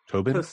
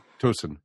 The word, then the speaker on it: Tosin: Well, that gets into, I know they've been talking about that Bradley and, how do you pronounce his name? Tobin? – Tosin.